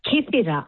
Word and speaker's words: Kythera: Now, I said Kythera. Hang on. How do you say the island again? – You said it right. Kythera.